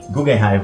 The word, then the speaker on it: Guggenheim: Seria, eu acho que é Google. Guggenheim. Guggenheim, vamos Sim. lá. Sim.